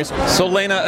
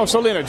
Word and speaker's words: Solena: Solena Estate. – Oh, Solena, just like your name, it's pronounced the same way. – Exactly, just like it's spelled.